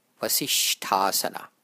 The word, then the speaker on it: Vasishthasana: The second S, shtha, is pronounced like the S in the word shout. See if you can hear it. Vasishthasana, vasishthasana.